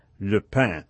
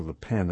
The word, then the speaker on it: Le Pen: With the decisive round in the French elections scheduled for this Sunday, and with the Front National, or National Front of Marine Le Pen, or Le Pen as most people pronounce it,